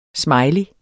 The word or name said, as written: smiley